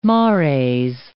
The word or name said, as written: mares